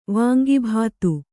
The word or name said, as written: ವಾಂಗಿಭಾತು